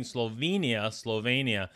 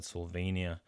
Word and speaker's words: Slovenia: I've been pronouncing it Slovenia. And it's not, it's Slovenia.